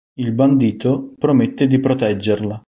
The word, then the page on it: bandito: italienisch